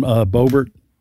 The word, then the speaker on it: Boebert: Because Trump's going to go after how do you pronounce the congressman? – Lauren Boebert. – Boebert.